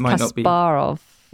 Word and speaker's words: Kasparov: I think in Russian it's Kasparov.